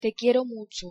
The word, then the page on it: te quiero mucho: Spanish